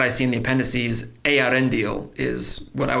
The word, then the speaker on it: Eärendil: So specifically this comes up because every time I hear you guys talk about Yarendel the Mariner, in my own mind and based on this, my pronunciation would have been something more like Eärendil, with the Ea, the same way that you pronounce the name of the world of creation, Ea. The E and the A sound should not be lumped together based on what I'm reading here.